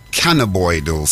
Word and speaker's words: cannabinoidal: And I played another clip about, yeah, melanin, how to, how to activate your cannabinoidal system. Did I pronounce that right? Yes, yeah.